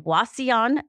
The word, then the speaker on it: Wauseon: And these two are here because they served on church staff together at Crossroads Evangelical Church in Wauseon, Ohio. Have I said that right? – Yes.